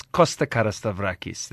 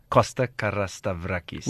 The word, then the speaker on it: Costa Carastavrakis: It's Costa Carastavrakis. That's how I'm known. Costa Carastavrakis.